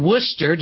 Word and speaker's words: Worcester: And he's in Worcester. Did I pronounce that right? – Oh, yeah.